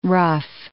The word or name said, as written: ruffe